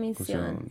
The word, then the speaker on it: Comisión: But, Comisión, am I saying that correctly? – La Comisión. – Yes.